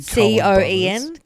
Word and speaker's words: Coen: The Coen brothers. – Did I say that wrong? – Like the Coen. Coen brothers. Yeah.